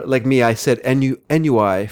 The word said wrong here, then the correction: ennui: for about 20 years until a friend one day said, "You know, it's pronounced ennui, right?"